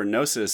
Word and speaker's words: Gnosis: And if anyone who's never heard of that is trying to find it, there's a G at the beginning. It's kind of spelled like Gnosis Safe, G-N-O-S-I-S.